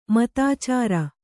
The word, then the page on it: ಮತಾಚಾರ: matācāra; Kannada